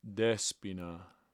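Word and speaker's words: Despina (Δέσποινα): Despina.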